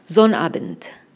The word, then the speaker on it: Sonnabend: Y casi siempre se comen la segunda mitad de la palabra y ustedes van a escuchar algo así como Sonnabend. Bueno, ahora la vamos a pronunciar más diferenciadamente.